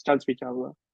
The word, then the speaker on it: Chansrichawla: I don't think there's a correct supposed to sound, because it's both a Thai and an Indian last name like put together. It's not have a right way to say it, so it's always hard for me to guide people, but it's Chansrichawla, yeah.